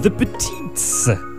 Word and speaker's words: The Petites: Spring Carol de la formation The Petites à la chance au coureur. Et oui, c'est bien comme ça qu'on nous dit de le prononcer dans leur document de presse. The Petites, The Mighty Antlers of Song EP.